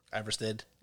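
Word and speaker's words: Ivarstead: How do you say that? – Iverstead. – I said it right.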